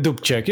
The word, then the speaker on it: Dubček: I don't know how to pronounce that. – Dubček, yeah, it's generally, Dubček.